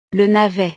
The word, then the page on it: le navet: frances